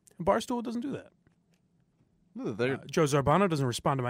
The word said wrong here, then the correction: Zambrano: Joe Zambrano.